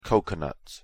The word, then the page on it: coconut: English